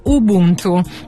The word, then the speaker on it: Ubuntu: Si pronuncia Ubuntu.